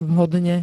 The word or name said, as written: vhodne